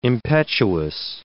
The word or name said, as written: impetuous